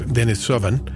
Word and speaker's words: Denisovan: And by the way, the pronunciation is Denisovan.